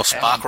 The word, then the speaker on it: Spock: Well, Spock rhymes with cock.